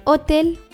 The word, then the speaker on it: hotel: Es ist im Deutschen gratis, im Spanischen gratis, das Hotel, im Spanischen el Hotel. Im Spanischen spricht man das H am Anfang nicht aus das Restaurant,